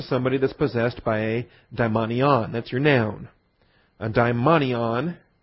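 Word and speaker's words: daimonion (δαιμόνιον): D-A-I-M-O-N-I-O-N, daimonion. Those don't look like N's, I know. Those look like V's. But those are N's. The Greek letter nu. So it's pronounced daimonion.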